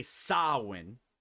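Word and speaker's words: Samhain: Pero, durante su más reciente pay-per-view, NWA Samoan, porque así es como se pronuncia para que no lo sepa,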